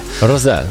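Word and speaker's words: Rosé: Bo jest literka, znaczy jest, nad literką E jest taka kreseczka. – Taki ptaszek. – Akcent na tą ostatnią sylabę. Czy to jest Rose? – Rose.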